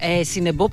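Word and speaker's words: Cinobo: Σινόμπο, μωρή. – Σινεμπό προφέρεται, Σινόμπο γράφεται.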